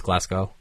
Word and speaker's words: Glasgow: I call it Glasgow. It's Glasgow. Like, oh, okay. The W is apparently silent.